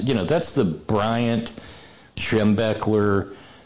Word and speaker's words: Schembechler: Schembechler.